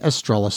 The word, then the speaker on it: Estrella: and that's Bijou Phillips playing Estrella Star. Spanish pronunciation.